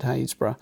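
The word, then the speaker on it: Happisburgh: It's spelled Happysburg, by the way, if anyone looks it up. – Yeah. – Happysburg. But it's actually Haysborough.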